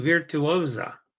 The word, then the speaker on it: Virtuosa: MSC Virtuosa is the proper pronunciation.